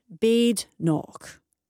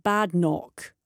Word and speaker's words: Badenoch: Badenoch, which is properly pronounced Badenoch, with emphasis on the ch, as in loch, not pronounced Badenoch.